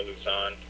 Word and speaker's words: Lauzon: Neil, forgive me if I mispronounce names, Lauzon, Lauzon, I-B-E-U-E-W. – You're very close with you. Afternoon, ladies and gentlemen. My name is Neil Lauzon.